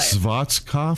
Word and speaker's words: Schwarzkopf: It's S-C-H-W-A-R-Z-K-O-P-F. – Now, he pronounced it to us as Schwarzkopf.